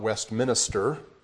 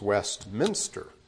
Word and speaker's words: Westminster: It's not Westminster, it's Westminster.